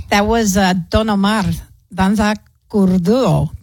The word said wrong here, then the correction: Kuduro: Danza Kudura.